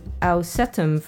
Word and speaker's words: Au7um: Track 4 was Ausetum from Russia. Actually, it's spelled A-U-7-U-M.